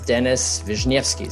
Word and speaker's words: Denis Vishnevskiy: His name is Denis Vishnevskiy. Is that how you pronounce it? – Yep, you got it.